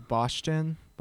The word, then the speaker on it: Bostian: It's like, it's German, first of all, so that's what makes it difficult. – Okay. – It's Bostian. – Bostian. – Yeah. You say it like it's spelled Bostian,